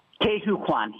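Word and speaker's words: Ke Huy Quan: Now, you say his name, Ke-Huy Quan, right? – Ke-Huy Quan, yes.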